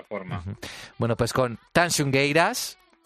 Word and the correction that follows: Tanxugueiras: ¿bien? ¿Lo he dicho bien? – Tansugueiras. Nos despedimos hasta el siguiente programa.